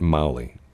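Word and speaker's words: Magli: But I think they pronounce it Mowley. I don't think you pronounce the G. – There's a G in there. – I don't think you pronounce it, though.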